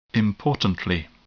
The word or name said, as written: importantly